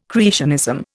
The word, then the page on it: creationism: Indian